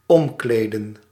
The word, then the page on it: omkleden: /ˈɔmˌkleːdə(n)/; /ɔmˈkleːdə(n)/